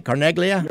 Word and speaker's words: Carneglia: Did I say that right, Carneglia? – You're right. – And you did. You got it right.